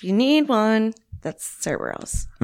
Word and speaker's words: Bareilles: Bareilles. – Bareilles.